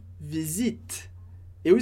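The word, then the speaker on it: visitent: Aqui, mais uma vez, importante lembrar, a pronúncia do visit. Eu escrevo ENT, porque a terceira pessoa do plural, eles, eles e elas, termina a conjugação em ENT, mas eu não pronuncio ENT.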